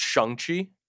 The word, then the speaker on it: Shang-Chi: Can I just say, apparently it's pronounced Shang-Chi. – What? – Yeah. And I think that's how they're going to be pronouncing it in the movie, Shang-Chi.